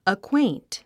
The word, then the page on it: acquaint: əkwéint; アクエイント